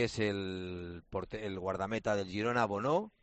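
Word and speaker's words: Bounou: y su, digamos que el nombre teóricamente, como se pronuncia, es Bonu.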